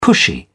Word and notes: pushy: British English

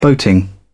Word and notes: boating: British English

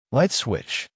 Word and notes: light switch: American English

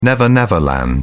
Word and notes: never-never land: British English

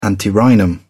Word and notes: antirrhinum: British English

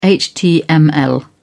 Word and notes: html: British English

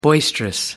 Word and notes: boisterous: American English

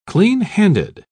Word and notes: clean-handed: British English

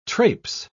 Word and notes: trapse: British English

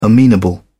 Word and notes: amenable: British English

